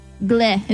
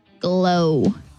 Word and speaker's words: Glo: How do you say this? G-L-O. Gleh. Is that how you pronounce it? Gleh fiber? Glow.